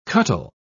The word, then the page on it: cuttle: British English